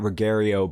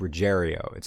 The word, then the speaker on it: Ruggiero: I've been pronouncing it Ruggiero, but it's Ruggiero.